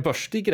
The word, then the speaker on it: Börstig: Uttalar jag Börstig rätt ens? Äh, ja, börstig säger man väl kanske mer eh, lokalt, men eh, annars nog helt rätt.